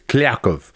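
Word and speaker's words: Kliavkoff: The proper Russian pronunciation is Kliakov.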